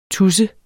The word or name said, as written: TUSSEN